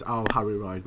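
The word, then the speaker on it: Ao Haru Ride: which is Our Harry Ride, which I can't pronounce. – No, you pronounced perfectly. Good job.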